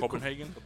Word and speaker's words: Copenhagen: The Copenhagen, I don't know if that's how you pronounce it. – Copenhagen. – There you go.